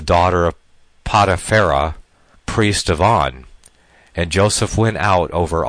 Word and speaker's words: On: So Egypt, and it's pronounced, On.